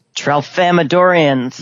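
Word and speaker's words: Tralfamadorians: Trelfamadorians. – Yes, there you go. I was trying to figure out how to pronounce that. Trelfamadorians.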